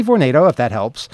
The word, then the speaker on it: Vornado: I understand it's pronounced Vornado. – Vornado, Vornado. – Well, I've always been saying Vornado. At the meeting, the people from the company were saying Vornado. I figure they probably know how to pronounce their name.